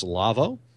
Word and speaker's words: Lawo: Now, that's spelled L-A-W-O, like Lawo, but it's pronounced Lavo.